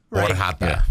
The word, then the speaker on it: Orxata: Orjata is the pronunciation of this, but it is spelled Orxata.